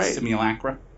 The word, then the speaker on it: simulacra: You said it right. – Simulacra? – Simulacra. Yeah, right. I think that's right.